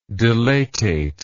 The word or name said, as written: dilatate